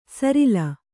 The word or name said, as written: ಸರಿಲ್